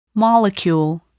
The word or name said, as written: molecule